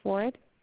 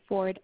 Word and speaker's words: Ford: My name is Janice Ford. – Janet? – Yeah, spell it for me. – J-A-N-I-C-E. – G-M-I-A? – J-A-N-I-C-E. – N-I-G-E-N-I. – C-E. Janice Ford, F-O-R-D. – F, oh, excuse me. F-O-R-D. D. Is there anything else? F-O-R.